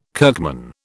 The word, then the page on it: kirkman: British English